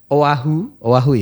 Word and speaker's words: Oahu: Jadi Bilal lahir di Oahu ya cara bacanya, teman Pro 2.